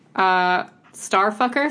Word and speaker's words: STRFKR: I've been listening to Starfucker, sorry for my French. STRFKR is how you spell it.